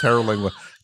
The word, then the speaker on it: Terlingua: Don't like how you adjusted your pronunciation of Terlingua, and we all know it's Terlingua.